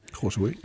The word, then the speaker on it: Josué: Jeg synes, man må sige, at Papes ægteskab med Josué, er det rigtigt udtalt? – Josué, ja.